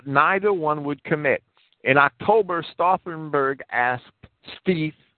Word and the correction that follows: Stief: I should be pronouncing his name Stief and not Stife, outright to kill Hitler, and Stief refused.